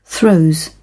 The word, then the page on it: throes: British English